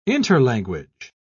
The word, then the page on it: interlanguage: British English